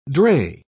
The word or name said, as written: dray